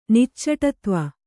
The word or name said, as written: ನಿಚ್ಚಟತ್ವ